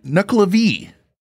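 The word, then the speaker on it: Nuckelavee: This one is called the Nuklevi. I believe I'm saying that right. Is that the correct pronunciation? – Yes.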